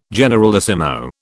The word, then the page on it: generalissimo: British English